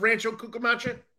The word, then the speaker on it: Rancho Cucamonga: So here, Friday the 21st and Saturday the 22nd and Sunday the 23rd, they're at Rancho Cucamonga.